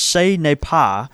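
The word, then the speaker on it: Ce n'est pas: He says, the quote from the T-shirt is pronounced "say ne pa."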